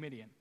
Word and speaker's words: Midian: It just happens to rhyme with Gideon, so it gets them confused sometimes. Gideon and Midian.